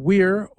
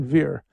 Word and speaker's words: vir: Like you can pronounce the word VIR, which means man, weir, or veer. I use the Italian or the church pronunciation, veer.